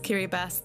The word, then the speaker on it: Kiribati: And yes, it is pronounced Kiribati. That's how it's pronounced in the local language Gilbertese.